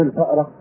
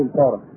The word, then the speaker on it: الفارة: وقيل لأعرابي: أتهجزون الفارة، يعني الواحد ينطق كلمة الفارة مش يقول الفأرة، يقول الفارة.